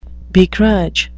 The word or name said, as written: begrudge